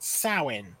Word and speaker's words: Samhain: Yes, so it's spelt Samhain or Samhain, but it's actually pronounced Samhain.